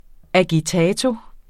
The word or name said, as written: agitato